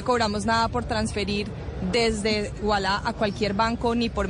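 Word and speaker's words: Ualá: Pero en la práctica, ustedes cargan su aplicación, UALA se escribe UALA, abre su cuenta, y empieza a usar Walla.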